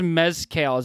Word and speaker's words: mezcal: What's this mezcal? Is that how you pronounce it? – Mezcal is how it's typically pronounced.